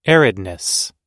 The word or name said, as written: aridness